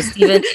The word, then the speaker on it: Stephen: So Steven is no S, so I'll spell it S-T-E-P-H-E-N.